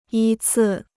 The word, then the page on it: 依次: yī cì